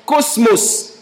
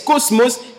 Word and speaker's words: kosmos: c'est un mot grec que l'on prononce « cosmos ». K-O-S-M-O-S, cosmos, qui veut simplement dire une manière de penser générale.